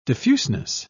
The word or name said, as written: diffuseness